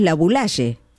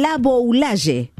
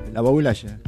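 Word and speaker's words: Laboulaye: Porque acá decimos la Boulaye, pero ustedes dicen la Boulaye. – Sí, claro. La Boulaye. – Así es la pronunciación correcta. – La O antes de la U, la Boulaye. – Toda la vida lo hemos dicho mal. La Boulaye.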